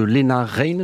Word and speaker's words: Lena Raine: Et donc ça, c'est un extrait de ta seconde émission autour donc de Lena Raine, je prononce bien.